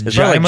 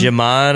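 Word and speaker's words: Djimon: Yeah, it's got Digimon Hansu. That's how I will always say his name. – I know, but I do think it's Jaimon. – Like Jimon. – No, yeah. I think it's Jaimon. Yeah. I think I heard that.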